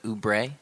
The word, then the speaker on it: Oubre: Is that how you pronounce it? Oubre? – Yes, I believe so.